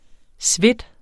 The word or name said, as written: svedt